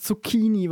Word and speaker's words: Zucchini: Ja, äh, Mir ist es ja auch eigentlich zu, egal, aber ich sage, sag, wenn ich heißt, mich entscheiden Das heißt nicht Zucchini, das heißt Zucchini, weil das ist italienisch und da spricht man das mit einem K aus. deswegen heißt das Zucchini.